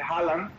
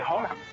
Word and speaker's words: Haaland: Claro, la G de Vingegaard no suena a la de medio, entonces, y las dos A, igual que todo el mundo dice Haaland, o sea, el jugador que va a fichar al Madrid, es Haaland. Las dos A es una O, entonces Vinegor.